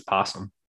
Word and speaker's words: opossum: I mean, it's spelt opossum, but it's possum.